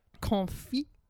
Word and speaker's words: confit: They have this amazing duck confit enchilada. Is that how you say it? – Yeah, you don't have to get all French with the confit.